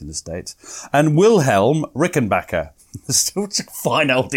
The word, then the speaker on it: Rickenbacker: Well, Edward Rickenbacker, as I call him, was born on the 8th of October 1890 in Columbus, Ohio.